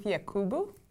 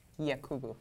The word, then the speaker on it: Yakubu: No, it's Ruth Yakubu. Yakubu.